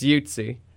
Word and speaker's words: Yutzi: Nope, you did not. You butchered it. – Oh, well, very good. I love honesty like that. So how do you say it? – It's Yutzi.